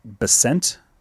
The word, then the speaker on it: Bessent: We had one more interesting secretary appointee here, and we have a Scott Bessent. Is that his last name? – I believe that's how you pronounce it. Yeah, who has been tapped for the Treasury Secretary, and he's an American investor, hedge fund manager.